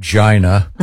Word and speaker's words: Gina: It's pronounced Gina.